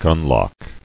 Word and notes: Gunlock: gun·lock

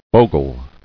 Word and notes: Bogle: bo·gle

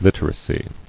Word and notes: Literacy: lit·er·a·cy

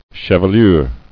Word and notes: Chevelure: che·ve·lure; Cheve*lure"

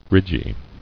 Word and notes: Ridgy: ridg·y

